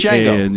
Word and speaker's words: Django: The D is silent. Just remember that. Spelled DJ. – Django.